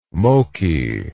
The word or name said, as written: moki